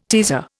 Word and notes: teaser: Indian

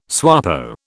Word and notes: SWAPO: British English

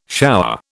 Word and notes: shower: British English

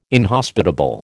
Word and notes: inhospitable: American English

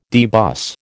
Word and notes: debus: American English

